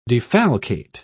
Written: defalcate

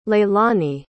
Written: laylani

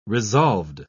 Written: resolved

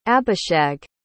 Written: abishag

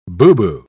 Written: boo-boo

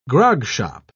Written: grogshop

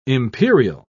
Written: imperial

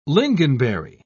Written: lingonberry

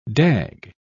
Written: dag

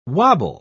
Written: wobble

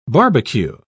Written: barbecue

